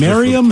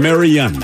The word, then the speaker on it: Marion: Miriam? How do you spell it? – Marian. Like Marion Barry. M-A-R-I-O-N.